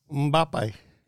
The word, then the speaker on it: Mbappe: Mbappe.